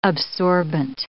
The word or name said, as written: absorbant